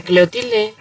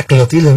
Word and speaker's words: Clotilde: ¿Era doña Clotilde o doña Cleotilde? – Doña Cleotilde. – Era Clotilde, ¿no? Acá es Clotilde.